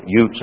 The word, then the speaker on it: Uz: Rejoice and be glad, O daughter of Edom, that dwell in the land of Utes, or Uz, should be Utes in pronunciation, or particular area.